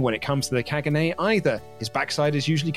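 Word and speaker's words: Caganer: It is pronounced Kaganay.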